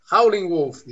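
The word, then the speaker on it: Howlin' Wolf: agora fiquei na dúvida, que é o, o homem da pronúncia, como é que é? howling Wolf, né? – howling Wolf, isso aí.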